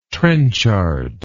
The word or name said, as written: trenchard